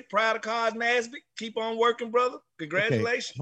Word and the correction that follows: Carl Nassib: You keep butchering his name. You can't butcher his name. – I butcher everybody's name. I call him Cos Nasby. Okay. Ain't that his name? – No, it's Carl Nassib.